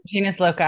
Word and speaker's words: genius loci: genius loci. Did I say that right? Genus loci? – Genus loci. – Mm-hmm. Okay, genus loci.